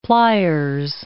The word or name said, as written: pliers